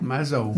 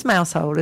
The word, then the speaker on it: Mousehole: How do you say it? – Mazel. – Ridiculous. I mean, it's Mousehole, isn't it? – Yeah. – Really? – No.